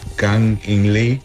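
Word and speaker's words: Kang-in Lee: Ah, espera, a ver si me recuerdo pronunciar su nombre correcto. Se llama Kang In Lee.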